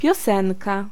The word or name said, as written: song